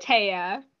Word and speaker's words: Tea: And that's the Tey-uh. See, it's funny because her name is spelled T, like, so we laugh about that one.